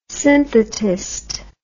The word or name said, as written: synthetist